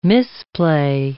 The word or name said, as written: misplay